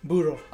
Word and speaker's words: burro: Am I saying it right? Burro? – Burro. – Okay. – B-U-R-R-O. – Okay. – I mean, I can't roll my R's, so I can't say it correctly.